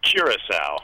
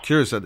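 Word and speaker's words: Curaçao: Now, this blue, how do you say it again? – Curacao. – Curacao.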